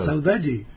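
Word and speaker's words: saudade: A ver, dígame cómo se dice. ¿Cómo es? A ver, dígalo. – Saudachi. – Saudachi. ¿Cómo se dice? – ¿Viste que no es salchicha? – Saudachi.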